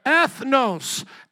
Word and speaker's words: ethnos: What is this Greek word? Pronounce this with me. Ethnos. Ethnos. Say it again.